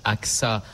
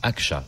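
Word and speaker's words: Ağca: Alors, ça se prononce AXA en français, mais en turc, Aksa, puisqu'il était turc.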